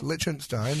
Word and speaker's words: Liechtenstein: Okay, so our next country, and I might butcher this name, but Liechtenstein? – She definitely will. – Yes, Liechtenstein. So Liechtenstein. I was close.